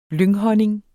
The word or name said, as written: lynghonning